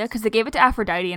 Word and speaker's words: Aphrodite: I can't have sex with you because, like Aphrodite, I was... Why did I pronounce that so weird? Aphrodite.